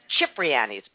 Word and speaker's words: Cipriani's: Like, because, you know, the restaurant in New York, Cipriani's. – Yeah. – It's right. – Actually pronounced Cipriani's, but nobody knows that.